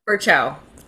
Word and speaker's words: Ferchow: Ferchow.